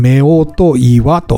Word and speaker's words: 夫婦岩: こちらは男女の岩と書いて、夫婦岩と読みます。